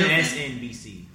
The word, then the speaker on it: MSNBC: MNSCC. – What? – MNSNBCS. – You know what I meant. Okay. – Wait, can someone say it right, please? – Please. – Say MSNBC.